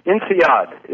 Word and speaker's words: INSEAD: It was close. It's INSEAD is the INSEAD. Correct pronunciation.